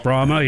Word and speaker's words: Brahma: We happen to pick up a little over a year Brahma I think steer. That's how it's pronounced, Brahma.